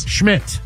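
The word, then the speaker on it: Szmyt: Cleveland won it 27-24 thanks to their kicker, Andre Smith, who hit a 56-yard field goal. – Syracuse legend, Andre Smith. – Oh, my bad. – Legend. – Is it Schmidt? – Schmidt, yes. It's spelled S-Z-Y-M-Y.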